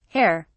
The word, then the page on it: herr: British English